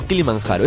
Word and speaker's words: Kilimanjaro: ¿Kilimanjaro o Kilimanjaro? Es Kilimanjaro porque la J en suajili se pronuncia G. Se pronuncia G. La J se pronuncia como la E. Kilimanjaro. – Como la Y.